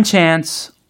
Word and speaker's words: chance: 네, 로지가 여기에서 one chance라고 발음을 했어요. 그죠? – 아, chance. – 어, one chance. – 아주 영국식이에요.